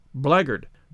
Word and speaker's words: blackguard: But the word blackguard isn't pronounced as it is spelt. It's pronounced blackguard.